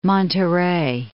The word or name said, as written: Monterey